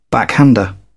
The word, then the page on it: backhander: British English